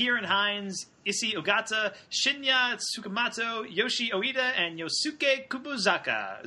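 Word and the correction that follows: Kubozuka: Zuka. I almost made it through all of those without messing up. – You were so close. – Almost. So close. – Last syllable. – The last one.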